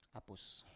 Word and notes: apus: Biak

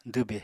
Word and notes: dwbe: apatani

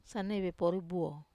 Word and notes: sanaivepori: Wamesa

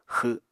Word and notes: xw7: Apatani

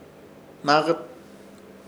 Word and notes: Hnaakɨt: Ishir Chamacoco

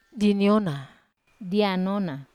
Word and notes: aniona: Wamesa

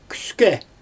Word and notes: küskE: Xyzyl

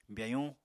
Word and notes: byayuñ: apatani

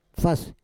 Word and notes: fas: Roon

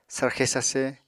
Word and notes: sarxe sarse: apatani

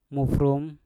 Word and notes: mufrum: Mpur

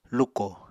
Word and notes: luko: Apatani

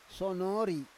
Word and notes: sonori: Roon

